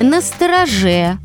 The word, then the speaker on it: настороже: Как правильно спрашивают нас? Настороже или настороже? Быть настороже. Ударение на последнем слоге.